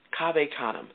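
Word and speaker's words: Cave Canem: Kaveh Kanem. It's spelled C-A-V-E, like cave, okay? Kanem, C-A-N-E-M.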